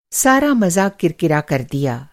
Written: किरकिरा

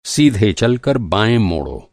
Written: सीधा

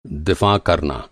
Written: दिफ़ा

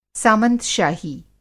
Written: सामंतशाही

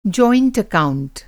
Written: एकाऊंट

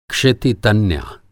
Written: क्षिति तन्या

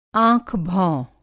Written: आँख-भौंह

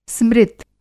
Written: स्मृत

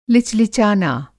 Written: लिचलिचाना

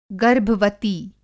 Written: गर्भवती

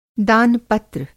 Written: दान-पत्र

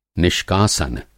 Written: निष्कासन

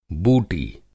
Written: बूटी

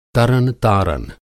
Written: तरन-तारन

